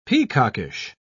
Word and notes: peacockish: British English

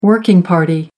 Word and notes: working party: American English

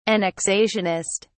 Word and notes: annexationist: British English

